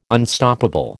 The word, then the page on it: Unstoppable: American English